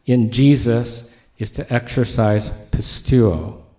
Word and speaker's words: pisteuo: I just realized I've been pronouncing it wrong for about 10 years. It's very humbling. The Greek word pisteuo, P-I-S-T-E-U-O. The first time I ever heard somebody teach on it, they pronounced it pisteo, and that's what I've been doing for 10 years. And if all else fails, look it up.